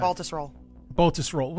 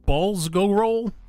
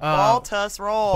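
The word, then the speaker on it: Baltusrol: Baltus Roll. – Wouldn't balls go roll? – Baltus Roll. – Balls go roll. – No, Baltus Roll. – Baltus Roll. Like balls, tuss. – Baltus Roll. – Baltus Roll. Got it.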